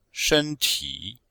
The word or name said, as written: shen1ti3